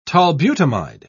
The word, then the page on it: tolbutamide: British English